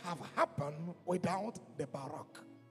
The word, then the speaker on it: barak: It's a word, barak, B-A-R-A-K, barak, but it's pronounced bow, barak.